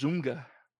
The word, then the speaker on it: ZOMFG: I like the way she said Z-O-M-F-G. – Yeah. Pirate Dave says that's pronounced Zoomga.